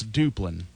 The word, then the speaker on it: Duplin: He says it's Duplin. – That's right. – Oh, I said Duplin. – Yes, he's Duplin. Yeah. – Okay. Well, that's how they say it around here, don't you know? – Well, I don't know.